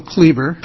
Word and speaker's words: Kliewer: Chair Walker and members of the committee, my name is Neil Kleber. – I pronounced it incorrectly. – The spelling is K-L-I-E-W-E-R, but pronunciation isn't that way.